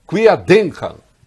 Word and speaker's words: Querdenken: E a grafia correta, Kerdenken, primeiro com Q-U, depois com K. Mas, no sotaque de bom, que é o meu, se pronuncia Querdenken, que quer dizer pensamento lateral.